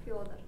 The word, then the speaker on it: Fyodor: Fyodor Dostoevsky, which, Anna, say it correctly. Fyodor. Yeah, not going to happen.